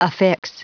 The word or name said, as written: affix